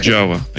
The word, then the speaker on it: Java: Java. Да, правильно говорить Java. Java это мотоцикл. – Java, извиняюсь.